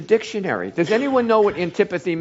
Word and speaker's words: antipathy: It was A-N-T-I-P-A-T-H-Y, and that is pronounced antipathy, can't even say it, antipathy.